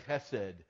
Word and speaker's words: chesed: The word there in Hebrew is for steadfast love is the Hebrew word chesed. We would spell it in English H-E-S-E-D.